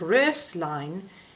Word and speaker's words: Röslein: First of all, the O with the umlaut over it in Röslein is pronounced by rounding your lips in an A formation and letting your tongue say E. So it's Röslein, Röslein.